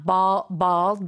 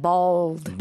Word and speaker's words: bald: So you have you have to do the L a little bit. So it's not bald, but it's like bald.